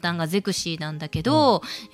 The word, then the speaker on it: ゼクシィ: ゼクシー